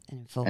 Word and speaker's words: Enninful: Oh, I always pronounce his name wrong. Is it Enninful? Yes, Enninful. Enninful.